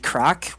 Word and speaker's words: craic: So, Irish word would be crack, which is spelled C-R-A-I-C, and it basically it has multiple meanings.